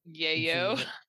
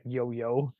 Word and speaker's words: Yayo: Yayo. – Yayo. I'm the worst at pronouncing Yayo. I'm sorry, Mr. Yayo. It's not Yo-Yo.